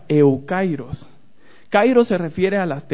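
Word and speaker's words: eukairos (εὔκαιρος): Esto tiene que ver con la palabra que encontramos que se llama, que se pronuncia eukairos. La palabra es una palabra compuesta por eu y kairos.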